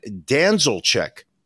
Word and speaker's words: Danzelczyk: We're going to talk about Brian Danzelchick, which is, I believe, the way he pronounces his name. I'll spell it. It's a Danzelczyk.